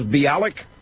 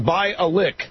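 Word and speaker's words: Bialek: I have been wrong in pronouncing the fourth Cain accuser's name as Bialik. Gloria Allred says that her name is pronounced Bialik. As in by a lick. – By a lick. – By a lick. It's B-I-A-L-E-K. I assumed it was Bialik, but Gloria Allred says her name is by a lick.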